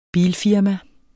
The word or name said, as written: bilfirma